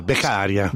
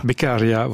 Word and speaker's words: Beccaria: Ouais, Beccaria. – Beccaria, voilà.